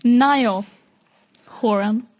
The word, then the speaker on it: Niall Horan: Niall Horan.